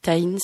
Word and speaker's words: Tahin: Taïn, ça s'écrit T-A-H-I-N.